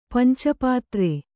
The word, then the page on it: ಪಂಚಪತ್ರ: kannada